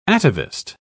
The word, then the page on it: atavist: British English